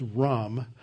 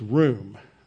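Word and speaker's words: room (רום): The first word that we see here is the word extol, which is based on a Hebrew word that looks to you like it's rum, but it's room, okay? That's a long U there for the vowel.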